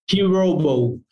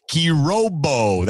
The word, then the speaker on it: Kirobo: What's the correct pronunciation of the brand? – Kirobo. – Kirobo.